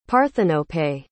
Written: parthenope

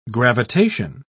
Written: gravitation

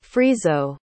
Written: friso

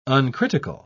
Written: uncritical

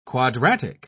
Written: quadratic

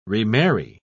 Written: remarry

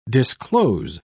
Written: disclose

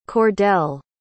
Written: Cordell

